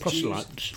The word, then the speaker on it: proselytes: Both proselytes.